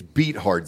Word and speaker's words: Beathard: Oh, Beathard. Sorry, man. You keep mispronouncing that. It's Beathard.